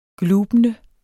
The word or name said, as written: glubende